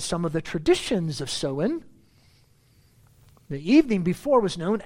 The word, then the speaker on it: Samhain: It originated with the ancient Celtic or Celtic festival called Samhain, and it's spelled Samhain, but it's pronounced as Samhain, when people would light bonfires and they would wear costumes to ward off ghosts.